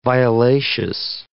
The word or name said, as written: violaceous